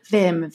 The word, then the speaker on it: Wim Hof: It took me a second to realize you were pronouncing Wim Hof in the actual, like, way that it's supposed to be pronounced. So I went, Vim, Vim, Vim.